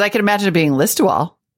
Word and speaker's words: Listowel: And he writes, I wanted to correct the pronunciation of the town, which sure looks like Liz Towel, but is pronounced Listowel. Even though it has a W, it's pronounced Listowel.